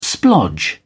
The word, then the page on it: splodge: British English